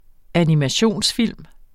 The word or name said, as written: animationsfilm